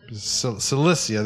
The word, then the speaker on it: Cilicia: Cilicia, there you go.